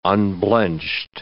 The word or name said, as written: unblenched